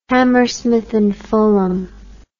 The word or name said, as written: hammersmith and fulham